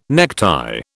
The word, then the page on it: necktie: British English